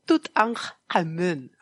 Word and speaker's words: Tutankhamun: Te lo voy a pronunciar como en japonés. O sea, te voy a pronunciar mm. intentando pronunciar toda la egipcia. ¿Qué te parece? – Venga. – Estaba yo sería? ahí. Tutankhamun. – Tut vale. Porque las pausas glotales esas son después del tut.